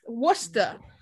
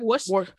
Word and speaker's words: Worcester: Worcester. Worcester, I think, is funny because when people try to pronounce Worcester, they're like Worcester. – Yeah, Worcester. – Worcester, like lemon, like everyone say like Lemonster.